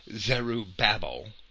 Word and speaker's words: Zerubbabel: Now in Zechariah chapter 4, Zerubbabel, and I've heard several pronunciations of that, but I will say Zerubbabel,